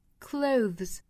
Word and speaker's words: clothes: 那我们大家更熟悉一种叫做 clothes， clothes，也就是好像在这个 cloth 后面加上了 es，它的发音呢就变化了，元音就变成了 clothes，那我们就要注意了，后面这个 th 就不是清辅音了，它就变成了浊辅音 z，并且后面呢因为有 s，所以是要发一个 z 浊辅音的 cloves。